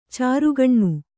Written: ಚಾರ್ಖಾನೆ

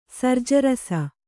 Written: ಸರ್ಜರಸ

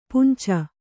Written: ಪುಂಚ

